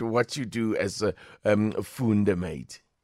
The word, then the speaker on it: Foondamate: Foondamate. – So Foondamate.